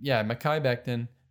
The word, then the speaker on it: Mekhi Becton: I mean, Joe Douglas, He drafted Mekhi Becton, Mekhi Becton. That's how his name is pronounced.